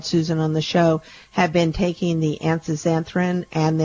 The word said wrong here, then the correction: astaxanthin: then also, did I say that right, Susan? – You're close. Astaxanthin.